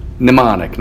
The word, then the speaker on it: mnemonic: memorial and mnemonic, not demonic, but mnemonic. It has a silent M on the beginning of it, actually.